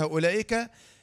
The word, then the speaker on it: أولئك: اللي بعدها أولئك، الشاهد فيها الواو دي مش واو مد، واو صفر مستدير إيه؟ لا ينطق.